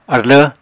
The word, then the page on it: arlv: Apatani